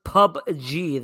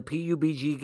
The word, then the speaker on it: PUBG: So apparently, it's pronounced PUBG, the PUBG game. – PUBG. That's what it's pronounced.